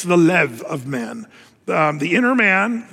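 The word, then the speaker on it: lev (לב): The Hebrew word, it looks like leb, but when you see a B in the Hebrew, it's actually more of a V sound. So when you say heart in Hebrew, it's lev. Lev is how you say that.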